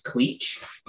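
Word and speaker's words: Cleetche: with a Spanish teacher who mispronounced Chris, and so he would call me Cleach, Cleach, and then of course my friends, you know, jumped on that, and pretty soon I was Cleach.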